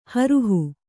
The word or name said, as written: ಹೊರಹು